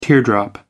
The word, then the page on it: teardrop: American English